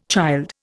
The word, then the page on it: child: Indian